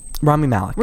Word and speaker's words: Rami Malek: Rami Malek. – We're going to go with Rami. – There you go. Rami Malek. I think you said it right the first time.